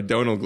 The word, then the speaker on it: Domhnall: This movie really needed Domhnall Gleeson. Sorry, Donald Gleeson. I forgot how to really pronounce it.